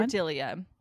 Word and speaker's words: Cordiglia: Cordelia. C-O-R-D-I-G-L-I-A. Yeah, I had to look it up. – Sounds right. – But I hear Cordelia, so apologies if that's wrong.